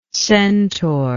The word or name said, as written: centauric